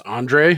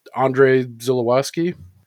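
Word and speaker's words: Andrzej Żuławski: But I, I went on to see how you actually pronounce this guy's first name because there's Z's and J's, but it's just Andre. Do I have that right? It's Andre Zulawski.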